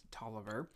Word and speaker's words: Taliaferro: While the name is actually pronounced Tolliver, it is spelled Taliaferro, which is one of those Civil War tidbits there.